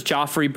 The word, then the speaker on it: Jophery: His name was Joffrey Brown, but it's spelled J-O-P-H-E-R-Y. So Joffrey Brown.